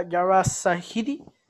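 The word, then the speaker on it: Yara Shahidi: Específicamente en Twitter, es que la actriz, si no me equivoco, se pronuncia Yara Sahidi, eh, va a estar interpretando a Tinkerbell en la live action.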